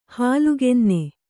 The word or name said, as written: ಹಾಲುಗೆನ್ನೆ